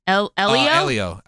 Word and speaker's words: Elio: I don't know how to say it. E-L-I-O? Elio? – I think it's Elio.